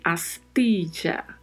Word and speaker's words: astilla: E atenção também para a pronúncia do TI. Lembre-se, em espanhol não temos o som de ti, como temos no português. Então fala-se sempre astilla, astilla. Pronuncia-se sempre ti.